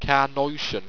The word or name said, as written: carnoition